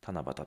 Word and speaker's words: 七夕: 7 月 7 日というと、日本では七夕の日として知られています。七夕、七夕というのは漢字で書くと七に夕方の夕ですね。このエピソードのタイトルにね、七夕っていう字を書いてあるんですけど、七に夕方の夕と書いて七夕と読みます。